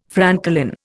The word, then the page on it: francolin: Indian